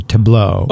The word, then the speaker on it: Tableau: T-A-B-L-E-A-U. Tableau. – Okay. – As in, Tableau. Yeah. – But the L's silent.